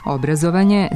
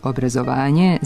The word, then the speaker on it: obrazovanje: Prema jednotomnom rečniku srpskog jezika Matice srpske, oba oblika, obrazovanje sa kratkouzlaznim akcentom na prvom slogu i obrazovanje sa dugouzlaznim akcentom na pretposlednjem slogu, jesu pravilna.